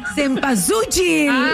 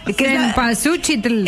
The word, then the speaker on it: Cempasúchil: Por ahí vas, pero no se dice así, flaca. – Si te la chuan. – Selpazuchitl. – Cempasúchil. – Cempasúchil.